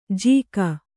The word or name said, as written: ಜೀಕ